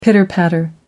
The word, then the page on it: pitter patter: American English